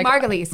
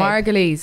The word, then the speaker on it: Margulies: Our little Miriam Margoyle. – Margulies. – Babe. Margulies, sorry. – No, but I like Margoyle because it rhymes with gargoyle.